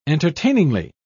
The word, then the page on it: entertainingly: British English